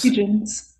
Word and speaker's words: Tietjens: Because the second one takes us into when, is it pronounced, Tietjens? – Tietjens. Tietjens seems to be how everyone pronounces it in my audiobooks and in the TV show.